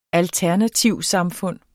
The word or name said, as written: alternativsamfund